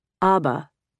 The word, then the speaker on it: Aba: To the extent that it appears on English language maps, Naba is referenced by its Chinese name, Aba, pronounced like the Swedish pop band.